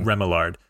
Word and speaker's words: Remillard: And we are saying that wrong. It's Remillard. – Is it Remillard? Dang. – Remillard.